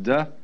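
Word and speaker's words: Te: And they could have spelled it D-A-O or D-O-W, but they didn't want the laity to be initiated. The next word is D, because again, there's no apostrophe after the T, so it's pronounced D.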